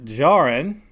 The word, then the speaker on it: Djarin: So it's he is the decommissioned Mandalorian hunter, Din Djarin. I guess he's going to get unchained. Din Djarin are unchained here in a little bit, but... – Carl! – Right? – The D is silent, you know.